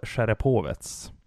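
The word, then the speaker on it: Cherepovets: Säsongen 15-16 med Severstal Sjerepovets. Mm. Hur tycker jag uttalar det förresten? – Ja, det var väldigt bra. – Ja, lite rysk touch ändå, va? – Ja, nej, men det var jättebra.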